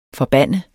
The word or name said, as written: forbande